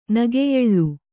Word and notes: ನಗೆಯೆಳ್ಳು: nageyeḷḷu

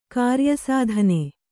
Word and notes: ಕಾರ್ಯಸಾಧನ: kāryasādhana

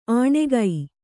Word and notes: ಆಣೆಗೈ: āṇegai

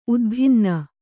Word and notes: ಉದ್ಭಿನ್ನ: udbhinna

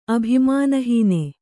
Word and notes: ಅಭಿಮಾನಹಾನಿ: abhimānahāni

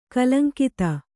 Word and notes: ಕಲಂಕಿತೆ: kalaŋkite

